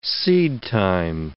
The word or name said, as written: seedtime